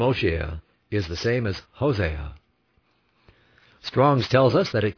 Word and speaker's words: Hosea: The name O-S-H-E-A, or Oshea, is the same as Hosea, H-O-S-E-A, or H-O-S-H-E-A, either way, pronounced Hosea, Hosea, which means deliverer, or salvation.